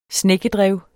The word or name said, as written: snekkedrev